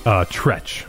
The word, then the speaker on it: Treach: was it Tretch or was it Treach? – Tretch is how you pronounce it.